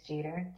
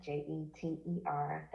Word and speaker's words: Jeter: And my last name is Jeter, Jeter.